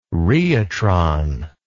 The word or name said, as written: rheotron